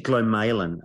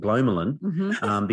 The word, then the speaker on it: glomalin: And it's actually Glamalan, apparently, is how it's supposed to be pronounced. And I had no idea how it'd be pronounced, because we say, you know, I used to call it Glomalan, because we'd only ever read it and never heard it talk about it.